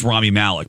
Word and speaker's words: Rami Malek: So Rami Malek was on, and I always wondered how he pronounced his name. – Yeah, and you're getting I think it's Rami Malek because he's getting ready to say his own name. – Okay. – So I trust him.